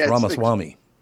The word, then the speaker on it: Ramaswamy: That's the reason why I didn't bring it up how to say it, because you said Vivek Ramaswamy, and his name is Ramaswamy. – It's Ramaswamy.